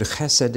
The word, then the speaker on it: chesed: In Hebrew, C-H-E-S-E-D, chesed. You pronounce the C-H like the Scots pronounce loch.